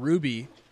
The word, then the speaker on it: RWBY: So, and now he's got his own show that is very popular with them, Ruby. Almost his anime kind of take on. – In case you guys don't know, it is spelled RWBY.